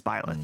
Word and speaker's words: Byleth: I think it's Byleth, right? – Yeah. – That's how you say it. – Bailiff. – I once said it wrong, and Holly very nicely corrected me, and now it's confused me, but it's Byleth.